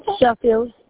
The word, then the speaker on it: Sheffield: Sheffield.